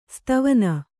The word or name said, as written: ಸಿತವಣ್ಣ